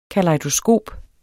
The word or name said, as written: kalejdoskop